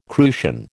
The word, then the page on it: crucian: American English